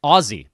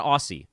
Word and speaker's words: Aussie: I've been told I need to pronounce it with a Z, not Aussie. It's Aussie. My best friend from high school married an Aussie. She corrected me quickly. Said, nah, man, that's a hard Z.